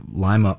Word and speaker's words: Lima: So you've heard of the city of Lima, Ohio, maybe. Turns out it was originally named after Lima, the city in Peru. But somewhere along the way, people started pronouncing it like the bean instead.